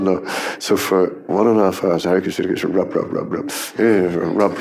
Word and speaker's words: Hare Krishna: Hare Krishna, Hare Krishna, Krishna, Krishna. – Yeah. Oh God, and then all the terrible pronunciations. Oh. Ay, ay, ay. Kare Krishna, Kare Krishna.